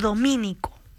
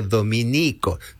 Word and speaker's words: Dominico: Si se alude a los religiosos de la Orden de Santo Domingo, se escribe Dominico, sin tilde en la I.